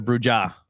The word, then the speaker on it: Brewja: So it's spelled like brew, B-R-E-W-J-A. Yeah. – Because the J in Spanish is the H sound. – Yes. Yeah. I first answered Bruja.